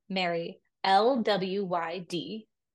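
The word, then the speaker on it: Mari Lwyd: And have you ever heard of the Mary Lwyd? – There. – Okay. So it is spelled M-A-R-I, Mary, L-W-Y-D, because it's Welsh and there's consonants everywhere. So Mary Lwyd.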